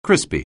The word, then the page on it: crispy: anglais